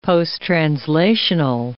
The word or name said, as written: posttranslational